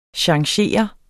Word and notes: changere: [ ɕɑŋˈɕeˀʌ ]; [ ɕɑŋˈseˀʌ ]

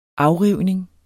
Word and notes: afrivning: -ˌʁiwˀneŋ

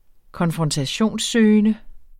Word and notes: konfrontationssøgende: [ -ˌsøːjənə ]; [ -ˌsøˀjənə ]

